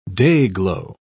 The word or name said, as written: dayglow